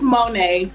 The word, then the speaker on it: Monet: It's Monet. Most people do say it like that, yeah.